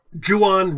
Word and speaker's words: Juan: Ju-on rhymes with true one. Yes. – Yeah, that's how you know it's pronounced. Because you can't say he is not the true one. I'll take our friend Don Juan.